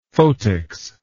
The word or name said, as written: photics